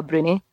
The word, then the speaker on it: Broonie: or as I would say, and I think it's quite closer to the Gaelic pronunciation, I would call it a Brownie.